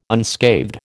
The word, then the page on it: unscathed: American English